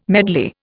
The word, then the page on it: medley: Indian